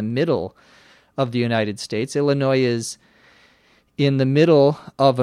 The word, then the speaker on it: Illinois: Illinois, I L L I N O I S, uh, is sometimes mispronounced as Illinois with an S sound. Illinois Illinois. The correct pronunciation, or the most common pronunciation, is Illinois with no S sound at the end.